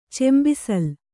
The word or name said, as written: ಚೆಂಬಿಸಿಲು